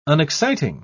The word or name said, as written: unexciting